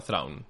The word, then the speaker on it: Traun: eh, jo crec que l'almirall Traun, ja es diu així, no? Traun? – Sí, jo pronuncia com a Traun.